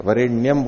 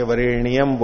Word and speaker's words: वरेण्यम: अच्छा, कई जने गायत्री मंत्र को बोलते हैं तत्सवितुर वरेण्यम, ऐसा बोलते हैं तो हम सुनकर के क्या कहते हैं, इनका उच्चारण ठीक नहीं आता है, वरेण्यम बोलना चाहिए, वरेण्यम बोल रहे हैं ये, तो ये तो उच्चारण भी ठीक नहीं कर रहे हैं,